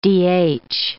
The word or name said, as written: DH